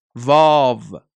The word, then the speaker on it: واو: و واو این طور تلفظ می شود. واو.